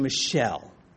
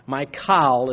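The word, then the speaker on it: Michal: Verse 29, and it happened as the Ark of the Covenant of Yahweh came to the city of David that, now I'm going to pronounce her name Michelle. Because that's how it works in the English. My Kal is closer to its pronunciation and as it is in print. But we'll go with Michelle.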